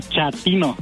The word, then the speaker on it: chatino: Chapino. – ¿Chapino? – No, chatino. – Es como chato, ¿verdad? Chatino. – Sí, azunero, como chato, chatino.